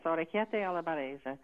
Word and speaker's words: orecchiette alla barese: So orecchiette alla barese? – Yes, orecchiette alla barese.